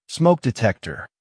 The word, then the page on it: smoke detector: American English